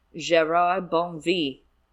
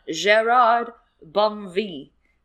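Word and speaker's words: Gérard Bonneville: I want to make sure the pronunciation by Philip Pullman is known. Gerard Bonnevie. – Oh. – Yes, that is like the pronunciation. – Like Emily in Paris. – That's what I said. Did you see me say that in the chat? By the way, I said that. I literally, yes, during the Blackwell's interview, Pullman confirmed the pronunciation is Gerard Bonnevie. It's very French.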